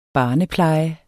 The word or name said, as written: barnepleje